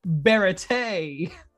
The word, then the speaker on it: Baratie: How do you say Baratie, Grandmaster? – I've always said Baratie, or I've heard Baratie, not Baratie.